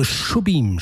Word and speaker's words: Jobim: Also Jobim spricht er sich auf. Ich habe das mehrfach falsch ausgesprochen und da haben mich dann ganz zauberhafte Hörerinnen und Hörer darauf aufmerksam gemacht, wie man den richtig ausspricht.